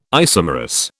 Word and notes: isomerous: British English

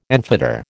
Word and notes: enfetter: American English

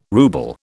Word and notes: rouble: British English

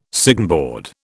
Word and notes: signboard: British English

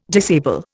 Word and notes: disable: Indian

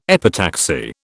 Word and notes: epitaxy: British English

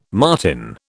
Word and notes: marten: British English

